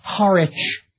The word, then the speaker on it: Harwich: Or the suffix which, pronounced without the W now, like Norwich or Harwich, in the Scandinavian settled communities, this ending would have been vik or wick.